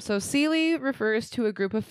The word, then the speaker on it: seelie: And when learning about the fae, you'll often come across these courts and the phrases seely and unseely. – Okay. – So seely is spelled S-E-E-L-I-E.